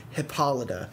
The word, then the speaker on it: Hippolyta: I'm sorry, that is a Greek word, and you are saying it wrong. The name is Hippolyta. – Preach. – If you were to look up how to say that word online, Google would say Hippolyta.